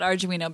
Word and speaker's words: Arduino: Tell us more about Arduino, because I love the name. – Okay. – I think I said it wrong. – You did? You said it right.